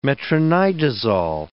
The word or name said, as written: metronidazole